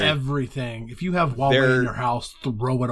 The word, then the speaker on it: Huawei: Did I pronounce that right? – Huawei.